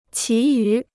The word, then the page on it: 其余: qí yú